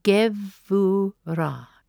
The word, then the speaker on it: Geburah: G-E-B-U-R-A-H. It is spelt with the B sounding as a V. Gevurah.